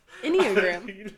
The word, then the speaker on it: Enneagram: What? Enneagram. – No, you said it right.